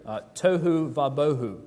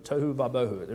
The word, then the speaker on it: tohu vabohu: It rhymes in the Hebrew, tohu vabohu, tohu vabohu. It rhymes in the Hebrew, and you see it in other places in the Old Testament.